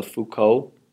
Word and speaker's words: Foucault: Did you know that was called the Foucault Pendulum? – Did I pronounce it wrong? – No, that's all I know about the pendulum, other than it's neat and I like it.